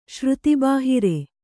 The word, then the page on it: ಶ್ರುತಿಬಾಹಿರ: śruti bāhira